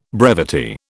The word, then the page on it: brevity: British English